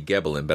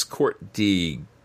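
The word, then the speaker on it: Court de Gébelin: In 1781, the French-Swiss Protestant clergyman and occultist Antoine Court, who named himself Courtiegeblin, I believe is how that's pronounced. It could be Gebelin, but I don't think so. It's Courtiegeblin.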